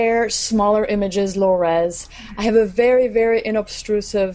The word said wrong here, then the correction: unobtrusive: is that how you say it in English? Inobstrusive? I think I'm saying unobtrusive? – Unobtrusive. Sorry, yeah.